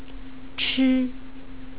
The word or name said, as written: chi